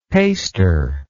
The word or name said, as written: paster